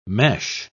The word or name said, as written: mesh